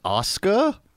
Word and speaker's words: Asuka: Obviously, I'm going to choose Asuka, if that's how you say it.